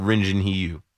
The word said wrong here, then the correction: Hyun-Jin Ryu: Or Hyun-Jin Ryu. Did I say his name backwards?